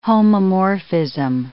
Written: homomorphism

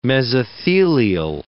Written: mesothelial

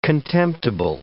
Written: contemptible

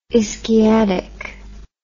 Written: ischiadic

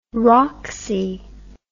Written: rocksy

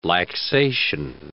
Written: laxation

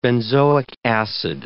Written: benzoic acid

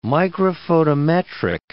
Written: microphotometric

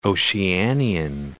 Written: Oceanian

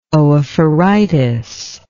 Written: oophoritis

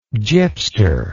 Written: gypster

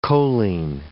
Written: choline

